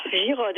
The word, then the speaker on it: Girod: Vous êtes habité à Tours, et c'est le nom Giraud qui se prononce Giraud, c'est ça? – Voilà, c'est ça, Giraud, et j'y tiens. – Bon, très bien, c'est G-I-R-O-D, et on prononce comme s'il y avait un E à la fin.